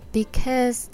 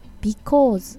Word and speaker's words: because: えーと、と次に「 Because this is the very first episode of my podcast」って言ってるんですけどここで発音のポイントです。 Because ってこうカタカナ英語にすると Because って言いがちなんです